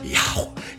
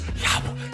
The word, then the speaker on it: Yahweh: You can't even pronounce his name. It's Yahweh. It's just Yahweh. It's just a breath.